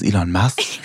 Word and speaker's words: Elon Musk: Elon Musk, heißt der, wird der so ausgesprochen? – Elon Musk.